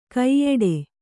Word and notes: ಕೈಯಿಡು: Kannada

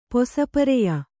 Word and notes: ಪೊಸಪರೆಯ: kannada

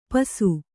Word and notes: ಪಿಸಿ: kannada

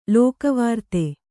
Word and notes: ಲೋಕವಾರ್ತೆ: lōka vārte

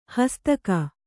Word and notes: ಹಾಸ್ತಿಕ: hāstika

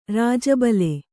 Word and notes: ರಾಜಬಲೆ: kannada

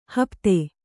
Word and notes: ಹಪ್ತೆ: hapte

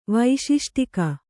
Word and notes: ವೈಶಿಷ್ಟಿಕ: vaiśiṣṭika

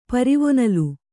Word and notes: ಪರಿವೊನಲ್: Kannada

